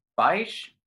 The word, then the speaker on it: Baisch: I'm pretty sure it's pronounced Baish. B-A-I-S-C-H. – Bish? – Glendive Dinosaur.